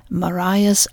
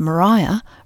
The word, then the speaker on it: Maria: originally charted in Dutch as Maria's Island, after the wife of Antony van Diemen, the governor of Batavia at the time. Hence the less usual pronunciation of Maria rather than Maria.